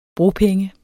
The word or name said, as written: bropenge